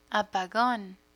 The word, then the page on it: apagon: spanish